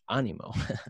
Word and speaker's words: ánimo: This word called ánimo. I guess if I was to pronounce it in Spanish, ánimo,